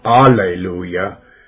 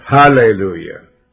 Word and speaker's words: hallelujah: And the 24 elders and the 4 beasts fell down and worshiped God that sat on the throne, saying, Amen, hallelujah, not alleluia, but hallelujah. Let's say it right.